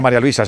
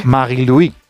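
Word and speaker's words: Marie-Louise: Bueno, no se dice María Luisa, se dice en su... – Sí. Marie-Louis.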